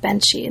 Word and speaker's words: Benshi: focuses mainly on Benshi. Am I pronouncing that correctly? – Yes. – Benshi, great.